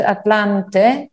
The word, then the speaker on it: Atlante: And that's the way we pronounce it, Atlante.